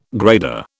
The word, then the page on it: grader: British English